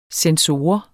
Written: censor

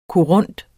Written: korund

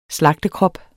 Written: slagtekrop

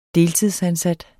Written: deltidsansat

